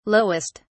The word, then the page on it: lowest: British English